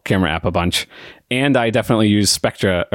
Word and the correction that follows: Spectre: or Spectre. Now, see, I've already mispronounced it. Uh, is it Spectre? it's Spectre. Spectre.